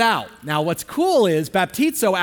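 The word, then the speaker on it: baptizo (βαπτίζω): In fact, the word baptize here comes from a Greek word, and it's pronounced baptizo. Can you say that? Baptizo.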